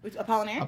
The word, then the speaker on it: Apollinaire: Apollinaire.